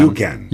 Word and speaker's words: UCAN: voilà, on prononce UCAN. You can.